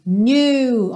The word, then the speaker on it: knew: Sweat dripping off me, before I even knew her name, knew, ok? O passado do verbo to know, knew, e o K é mudo, assim como no presente, know, ok?